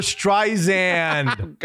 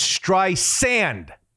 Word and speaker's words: Streisand: Siri was pronouncing her name Barbara Streisand. – God. Yeah. – She got him on the phone and she said, Mr. Cook, my name isn't spelled with a Z. It's Streisand. Like sand on the beach.